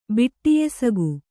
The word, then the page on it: ಬಿಟ್ಟಿಯೆಸಗು: Kannada